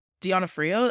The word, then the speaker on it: D'Onofrio: But Bill, Vincent, how do you say his name? Donofrio? D'Onofrio? Like, again, D'Onofrio. – that's how I say it.